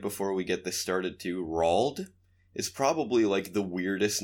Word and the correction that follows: Roald: wasn't sure how to pronounce it, but Rald sounds like it is it. – Rald Dahl. – Roald Okay.